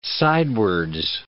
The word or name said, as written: sidewards